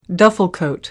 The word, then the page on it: duffel coat: American English